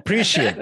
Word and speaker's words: prescient: But I must say for her that most of her warnings turned out to be how do you say sofotatid? – I'm a prophet. – Prescient.